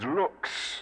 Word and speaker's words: LVX: LVX, pronounced as looks, looks, looks.